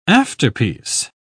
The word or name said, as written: afterpiece